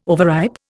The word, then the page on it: overripe: Indian